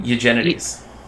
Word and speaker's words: Eugenides: Eugenides.